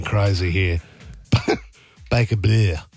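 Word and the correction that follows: Baker Bleu: Am I saying that correctly, Tom? – How do you spell it? – It's not Baker Blue, is it? – Baker Blue. – Is it Baker Blue? – Yeah, I think it's spelled B-L-E-U. – A bit of a French bit on it.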